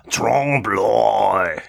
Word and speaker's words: trompe l'oeil: It's pronounced Trompe l'oeil.